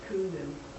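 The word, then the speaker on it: Kundun: It's called Kundzin. Oh, man, don't ask me to spell that. K-U-N-D-Z-I-N. Kundzin. Is that it? – I thought it was just Kundin. – Kundin. K-U-N-D-I-N. Kundin. K-U-N-D-I-N.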